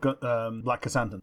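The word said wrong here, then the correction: Krrsantan: Yeah, that's the one, because it's not spelt like that. – Yeah, well, it's Krr-Santan.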